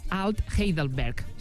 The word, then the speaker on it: Alt Heidelberg: i és que el bar es diu Alt Heidelberg. Sergio, bona tarda. – Hola, bona tarda. – Es diu així, que jo és que l'alemany em porta una mica...